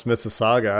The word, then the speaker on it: Mississauga: So you can tell that I'm not Canadian because I don't know how to pronounce Mississauga. I don't know if that's correct or not. – You're right on that because he's not far from me then.